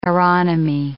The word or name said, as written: aeronomy